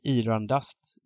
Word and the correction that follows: Irandust: Irandust. Irandust. Irandust. Irandust. Irandust ja.